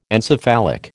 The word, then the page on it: encephalic: American English